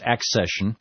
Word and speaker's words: accession: By the way, Narlodius points out my mispronunciation. It's accession.